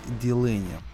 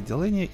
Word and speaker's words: Делэни: Сегодня речь пойдет про Самуэля Рэй Дилени. У него фамилия по-всякому, по-разному. по всякому по разному произносится в наших книгах. Ну вот я беру информацию из Википедии, поэтому Самуэль Рэй Делэни, и сегодня я хочу прочитать его рассказ, который, естественно, победил э, в премии а, Хьюга за лучший короткий рассказ.